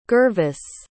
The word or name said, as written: gervase